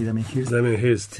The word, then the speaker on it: Damien Hirst: Damien Hirst, sí.